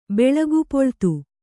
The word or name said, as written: ಬೆಳಗುಪೊಳ್ತು